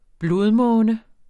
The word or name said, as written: blodmåne